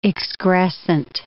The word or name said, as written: excrescent